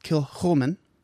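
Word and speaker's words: Kilchoman: this is pronounced Kilhoman.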